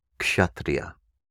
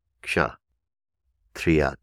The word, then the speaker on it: Kshatriya: The next caste to consider is Kshatriya. Kshatriya is spelled K-S-H-A, Ksha, Triya, T-R-I-Y-A, all one word, Kshatriya. The next caste is Vaishya, V-A-I-S-H-Y-A, Vaishya, Vaishya.